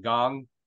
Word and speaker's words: Kang: Yeah, but he actually pronounced it Gong.